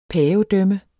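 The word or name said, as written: PAVEDØMME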